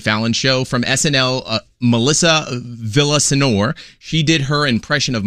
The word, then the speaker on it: Villaseñor: Villaseñor.